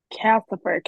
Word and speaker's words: Calcifer: I think I'm mispronouncing it, but it's like Calicifer, something like that. – Calcifer, Calcifer.